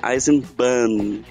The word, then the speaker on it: Eisenbahn: Então a pronúncia correta seria Eisenbahn, com N, igual Autobahn, com N. E eu também falo meio nasalado, então às vezes pode parecer que você engole o N, mas sim, ele deve estar presente.